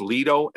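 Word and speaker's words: Leidos: I didn't get into the article, but it said the company was, I think it's pronounced Leido, L-E-I-D-O.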